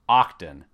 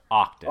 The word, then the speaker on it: Oqton: They're called Octon. Now, it's spelled a little different. O-Q-T-O-N. Octon. – Octon. – Octon. Sounds like a Klingon.